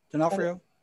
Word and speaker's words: D'Onofrio: And it started out with Lily Taylor going to marry Vincent De Arfornio. Is that how you say his name? – D'Onofrio.